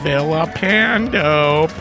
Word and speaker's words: Villapando: There's Vincent Villapando, Pronounce with the L's, making an L sound, not a Y sound.